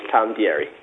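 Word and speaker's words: Tom D'Eri: My name is Tom Dieri.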